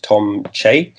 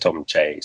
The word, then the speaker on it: Tom Choi: So this is a voice actor named, he's a Korean-American actor named Tom Che. It's spelt Tom Choi, but I looked into this because I didn't want to butcher it. It's actually pronounced Tom Che.